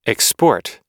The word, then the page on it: export: American English